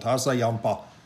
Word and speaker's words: Hassayampa: Hassayampa River Reserve. – That was better. – Yeah, well, you got to put some kind of an accent into it. Hassayampa River Preserve.